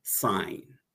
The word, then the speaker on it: sin: So we write it sin, but we pronounce it sign.